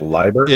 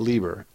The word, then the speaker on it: Leiber: Did you just say it correctly with Leiber? – It is Leiber, not Lieber. And the last time I looked in the English language, the word die was spelled D-I-E.